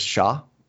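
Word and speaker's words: Char: Shar was Shah, which is actually, as they point out in the article, that's much closer to how they pronounce his name in Japanese, right?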